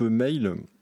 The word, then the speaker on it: MHAOL: Alors, moi, c'est un album que j'attendais pas mal, c'est le groupe Mail. Alors qui s'écrit M-H-A-O-L, qui est plutôt un groupe féminin.